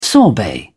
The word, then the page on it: sorbet: British English